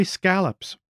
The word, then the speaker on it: scallops: Bay scallops, Marvin said, but pronounced it the off Cape way instead of rhyming it with wallop, and we all stared at him.